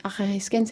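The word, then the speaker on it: Arariskein (ἀραρίσκειν): Quand on remonte vers un vieux radical indo-européen, qu'on retrouve dans le grec Arariskein, ouais, Arariskein, c'est pas simple à prononcer, mais c'est bien ça.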